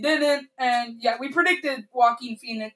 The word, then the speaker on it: Joaquin: Did I say that right? – Yes, it's Joaquin Phoenix.